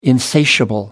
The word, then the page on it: insatiable: American English